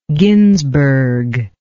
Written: ginzberg

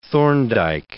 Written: Thorndike

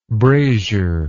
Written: brasier

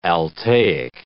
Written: Altaic

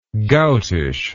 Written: goutish

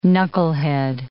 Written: knucklehead

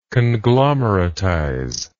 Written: conglomeratize

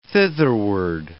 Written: thitherward

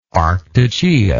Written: arctogaea

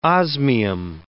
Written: osmium

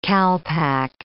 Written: calpac

